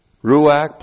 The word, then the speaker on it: ruach: But if you're going to pronounce this like a Jew, it would be ruach.